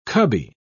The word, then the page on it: cubby: British English